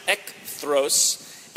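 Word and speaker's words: ekthros (ἐχθρός): The word for enemy there is pronounced ekthros, and it means someone in opposition to you, someone who might be hostile to you.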